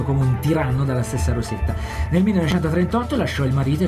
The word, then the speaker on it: Rosetta: Rosetta, detto in con la pronuncia inglese.